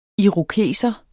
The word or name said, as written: IROKESEREN